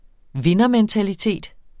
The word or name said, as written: vindermentalitet